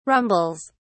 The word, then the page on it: rumbles: British English